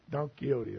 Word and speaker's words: Don Quixote: Don Quixote.